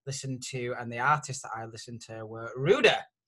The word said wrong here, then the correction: Ruadh: Have I said that correctly? – No, it's okay. Rua, there we go.